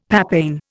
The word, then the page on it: papain: Indian